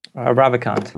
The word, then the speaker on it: Ravikant: Ravikant.